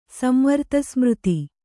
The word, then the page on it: ಸಂವರ್ತಸ್ಮøತಿ: Kannada